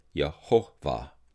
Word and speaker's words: Yehovah: some pronounce it Yahweh, with a V being more of a W, and others pronounce it Yehovah, and using the consonants.